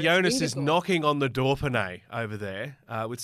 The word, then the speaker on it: Dauphiné: Dauphiné. – Well done. – How's that? Not bad. – There's no R in Dauphiné, but I'll take Dauphiné.